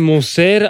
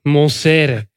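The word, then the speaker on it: Monseré: Ja, en hoe spreek je dat weer uit? Monserre, alsjeblieft, zeg. We hebben het hier over een ex-wereldkampioen. Jean-Pierre Monserre. Monserre.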